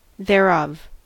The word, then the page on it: thereof: American English